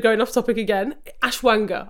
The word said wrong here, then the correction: ashwagandha: I don't know if I'm saying it right, but this is ashwagandha. Ashwagandha, yeah. thank you. Ashwagandha.